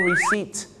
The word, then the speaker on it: receipt: ตัว P กับตัว B นะครับหลายๆครั้งไม่ได้ออกเสียงนะฮะอย่างเช่นคำนี้ครับคำว่า receipt ที่แปลว่าใบเสร็จนะครับบ้านเราชอบออกเสียงว่า receipt นะฮะ receipt ไม่ใช่ receipt